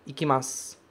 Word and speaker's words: ikimasu: Ikimasu. Esse u não é pronunciado como u. Ele some, ele deixa lá, fica só, assim, sutilmente a ideia de que um dia ele esteve ali. Ele é escrito, mas a gente não pronuncia.